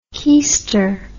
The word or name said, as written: kiester